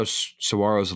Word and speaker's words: saguaro: The giant saguaro cactus. – Saguaro. – Saguaro cactus. – Silence. G is silent.